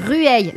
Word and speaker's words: Rueil: Comment on prononce? – Rueil. Voilà. – Rueil. – Et ben ça c'est dans mon spectacle. Les gens disent Rueil, mais ça s'écrit R U.